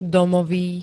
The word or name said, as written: domový